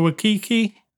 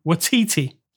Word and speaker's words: Waititi: So directed by Taika Waititi. I still say that wrong every time. Waititi, let's say.